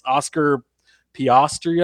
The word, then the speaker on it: Oscar Piastri: Oscar, I'm gonna, definitely going to butcher his name, but it's Oscar Piastri, I think is is how it is. Good job.